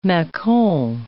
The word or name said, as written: macon